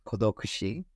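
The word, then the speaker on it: kodokushi (孤独死): Yes, so it's pronounced kodokushi, I think.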